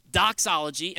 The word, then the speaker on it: doxology: It rhymes with theology. Doxology.